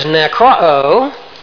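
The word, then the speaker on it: nekro (νεκρόω): Now the word here is nekro, N-E-K-R-O, actually double O, long O on the end.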